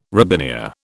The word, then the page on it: robinia: British English